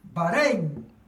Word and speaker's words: Bahrein: Tiene acento en la E. Es palabra grave. Bahrein.